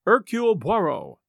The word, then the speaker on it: Hercule Poirot: his name being Hercule Poirot. And yes, I checked, and that's how his name is usually pronounced.